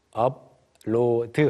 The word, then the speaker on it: upload: 그 앱 안에서 사람들이 뭘 할 수 있냐면 people can upload, 우리가 흔히 이제 외래어로 upload 한글로 쓰고 나면 아. – 발음이 한국어 내에서는 업로드처럼 미음 발음으로 바뀌죠. – Yes, but we're upload. – 네, 그런데 영어에서는 up, P 발음을 살려줘야 됩니다.